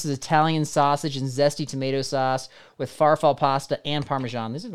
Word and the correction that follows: farfalle: Did you say right pasta? How do you pronounce it? Farfalle. – that's way better, but yeah, go with that.